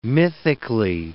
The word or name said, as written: mythically